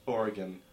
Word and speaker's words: Oregon: No, that's how it's pronounced. – Oregon.